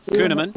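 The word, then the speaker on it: Kuhnemann: And I will give a credible mention to Matt how do I pronounce that? Kuhneman? – Yeah, Kuhneman.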